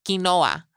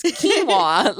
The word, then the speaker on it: quinoa: She pronounces it interestingly. It's she says quinoa. – That's what I used to say. That's what I used to say. – It's like with like a almost like a Chicano yeah, accent like, or something. That, like yeah. I don't know, quinoa. And I was like, it's quinoa. Like she's like, yeah. I was like, maybe that's how you say it in Spanish.